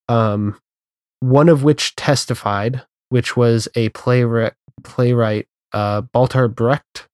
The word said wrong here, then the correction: Bertolt Brecht: I'm probably mispronouncing his first name. – Bertolt Brecht. He was a German. – Bertolt Brecht, yeah.